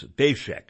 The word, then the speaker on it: Daszak: I had been mispronouncing his name, Daszak, D-A-Z, or D-A-Z-S-A-K, it's Daszak.